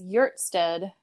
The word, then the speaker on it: Hjertstedt: So my last name is Hjertstedt. It starts with an HJ, which is pronounced like a Y.